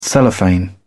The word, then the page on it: cellophane: British English